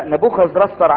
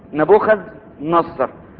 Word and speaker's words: نبوخذ نصر: انتوا واخدين بالكم من كلمه رسر؟ مش احنا قلناها دي قبل كده؟ النطق العبري نبوخذ نصر، لكن ده وصل بقى خلاص وقعد وارتاح فابتدوا يستخدموا الاسم بتاعه الاسم بتاعه اللي هو يعني بالنطق البابلي.